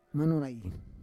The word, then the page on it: manorai: Roon